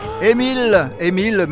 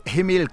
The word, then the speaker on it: Amiel: Émile, mais ça s'écrit Amiel, mais Émile Cartwright,